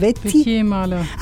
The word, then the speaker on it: Wettier-Mahler: Wie spricht man das aus? – Wettier-Mahler. – Ah, Wettier. – Das war ein französischer Name. Wettier-Mahler, genau.